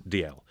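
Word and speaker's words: Dalziel: The correct pronunciation is D.L.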